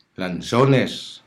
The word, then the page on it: lanzones: Hiligaynon